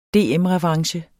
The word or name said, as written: DM-revanche